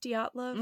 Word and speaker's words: Dyatlov: So you guys may have gathered that today we are going to talk about the Dyatlov Pass incident. And is that how it's pronounced? Dyatlov? – Mm-hmm. Yeah, I'm pretty sure.